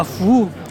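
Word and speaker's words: fou (福): Du caractère fou ? Ah, fou ! Ah oui, fou, oui, ça veut dire bonheur, excusez-moi, j'ai pas compris. – J'ai mal prononcé. – Avec un peu d'accent, oui.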